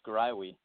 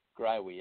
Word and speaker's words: Greiwe: No worries. It's Grywee. Grywee. It's a tough one. Gry rhymes with Y.